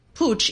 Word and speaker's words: Puig: Oh, and by the way, you do pronounce it Puig, even though it's spelled P-U-I-G.